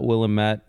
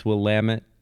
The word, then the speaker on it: Willamette: I remember one older gentleman was like, it's not Willamette, it's Willamette, damn it.